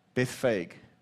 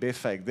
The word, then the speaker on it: Bethphage: Bethphage. Okay, good, Bethphage.